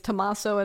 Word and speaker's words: Tommaso: Well, according to one Italian listener, I don't either, but it's Tommaso and Francesco Buttis.